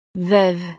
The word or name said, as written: veuve